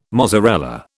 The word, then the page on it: mozzarella: British English